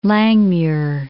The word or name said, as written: Langmuir